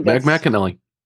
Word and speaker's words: McAnally: I believe it was, I forget how to pronounce this, McAnally. – That's right, McAnally.